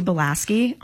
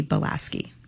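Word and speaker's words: Bialeschki: So I am just at Marcy Belaski on both Facebook and Instagram. You know, I go by Marcy B, but I'm at Marcy Belaski.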